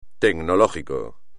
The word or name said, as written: tecnológico